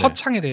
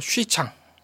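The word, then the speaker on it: 许昌: 허창은 중국 발음이 어떻게 됩니까? – 쉬창. – 쉬창. – 쉬창. – 아, 네. 허니까 그냥 허로 시작할 것 같은데 허가 아니라 쉬로 시작하는 네, 거예요. XU 이렇게 되니까 쉬창이 되겠죠.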